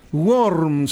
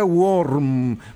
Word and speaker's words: Warm: Worm, se escribe W-A-R-M, se pronuncia Worm. Por eso van a escuchar ese Wonder Worm. Pero se escribe Wonder y seguido W-A-R-M, que es algo así como maravilla calentita o tibiecita, lo que quiere decir esa conjunción de palabras.